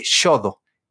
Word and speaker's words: Xodo: Se escribe con X, se escribe Shodo. X-O-D-O. Shodo está disponible y gratis en Mac, en Windows, en Android, en iPad en Windows me encanta porque puedes escribir, hacer trazos.